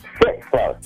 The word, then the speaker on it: Sitzler: Oh, you said Elizabeth Stickler? – Sitford. – Spell that for me, honey, so I don't mess it up. – S-I-T-Z-L-E-R. – Oh.